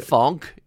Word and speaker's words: phonk: Edison's, it's spelled the number 3, capital D-I-S-O-N 5. Got it? – And good. Funk is? – Well, of course, it's P-H-O-N-K. Funk.